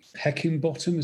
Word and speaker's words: Heckingbottom: But I think Heckingbottom, is that his name? I pronounced that wrong. Someone correct me, please. – No, it's right. That's right. – It's right. Good, it's right.